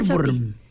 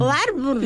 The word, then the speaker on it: Ohrwurm: Se escribe O-H-R-W-U-R-M. – ¿Pero tú sabes alemán? – Warburm. Warburm. Warburm. – Warburm.